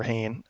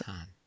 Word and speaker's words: Hahn: Nick Offerman makes a showing, and Catherine Hahn, or Hain, however you choose to pronounce it. – Definitely. There's no choice. It's Hahn.